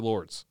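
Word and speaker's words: Lourdes: You think Waseca over Lords? – I think so. Lords. The S is silent. I don't know why. I just say it how the Romans say it. – Or the French say it, maybe.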